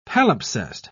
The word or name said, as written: palimpsest